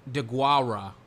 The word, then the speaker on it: Deguara: The third round pick, Joshua DeGura. I mean, I don't know anything about this guy. What does he do exactly? DeGuara. Apparently that's how you say his name.